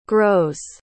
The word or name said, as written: grows